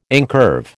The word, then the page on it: incurve: American English